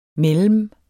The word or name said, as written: mellem